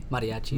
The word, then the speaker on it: mariachi: So how do you pronounce it? Mariachi? – Mariachi.